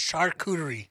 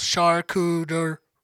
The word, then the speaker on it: charcuterie: I know how to say it. It's charcuterie. – Okay. – Three syllables. Charcuterie.